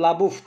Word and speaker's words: LaBeouf: Şimdi şöyle hani ben başına dedim ya bu Libaft olabilir, Labuft olabilir diye soyadı. Neden biliyor musunuz? Çünkü Fransız Amerikan şeyinden kökeni oralardan geliyor. Şimdi Fransızca'da Labouf diye okunuyor.